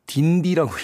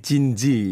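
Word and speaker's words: Dindi: 스펠사로는 D-I-N-D-I라고 표기가 돼서 예전에는 딘디라고 읽었다가 음악을 들어보니까 이제 발음이 진지 이렇게 라는 걸 듣고 나서 어, 진지로 발음했던 그런 에피소드가 아, 생각이 납니다.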